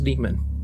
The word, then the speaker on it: daemon: Well, and and just to say, that's actually how it's it's it looks like daemon, but it's pronounced demon.